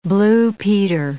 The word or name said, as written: blue peter